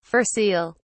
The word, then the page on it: fur seal: British English